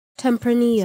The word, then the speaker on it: Tempranillo: I didn't have any whiskey in the pantry, so I'm sipping a little Emilio Maro Tempranillo. I don't know if that's how you even say it. – How to pronounce this? American accent. – Tempranillo.